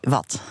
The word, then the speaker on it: Watt: Dat is Elvis Watt, zeg ik het goed? Of ja? Elvis Watt? – Nee, Watt.